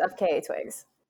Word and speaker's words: FKA twigs: I don't know how to say this person. FKA Twigs. – Yes, it's FKA Twigs. – FKA Twigs, really? – Yeah, that's how it's, yeah.